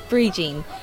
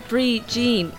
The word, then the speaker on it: Brie Jean: because it's spelt B-R-I-F-A-D-I-F-A-D-N. – Yeah, Brie Jean. So I'm going to get a little slice of Brie cheese and plus with a pair of jeans, so everyone can say it right.